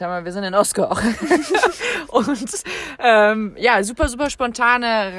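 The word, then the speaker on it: Hossegor: Aber wir sind in Ossegor oder auch französisch ausgesprochen Ossegor. – Ich hab gestern tatsächlich gelernt, die Einheimischen hier sagen Ossegor, aber okay. Vor allem so die Pariser Leute sagen Ossegor.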